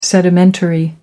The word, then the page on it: sedimentary: American English